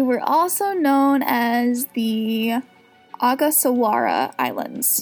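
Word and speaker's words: Ogasawara: That would be the Agasawara. – Agasawara.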